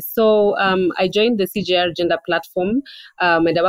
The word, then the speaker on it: CGIAR: Is that how it's pronounced? CJR. Yeah, they have a gender platform.